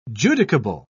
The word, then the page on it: judicable: British English